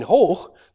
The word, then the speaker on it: hoch: But in hoch, this final consonant is actually pronounced, whereas in high, it's not.